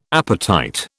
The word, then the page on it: apatite: British English